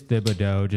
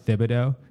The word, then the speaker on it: Thibodeau: If Chicago Bulls really did fire Thibodeau, and it is Thibodeau, just in case his nickname is Tibbs, but his name is actually, that's how you pronounce it, Thibodeau. – Yep. – You say the H.